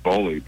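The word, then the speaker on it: Boehly: Is that why I saw your tweet on the co-owner of the Dodgers, Todd Beeley? Is that how it's pronounced? – I think it's Boley, but he's Boley. The co-owner, right? Todd Boley.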